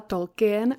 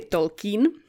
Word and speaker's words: Tolkien: Pozor na tu výslovnost, taky jsem celý život říkala Tolkien, ale eh, zjistila jsem, že opravdu ta správná výslovnost je Tolkien, takže doufám, že se nepřeřeknu moc často.